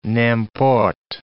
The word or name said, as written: n'importe